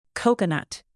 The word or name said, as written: coconut